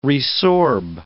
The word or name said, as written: resorb